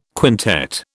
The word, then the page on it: quintet: British English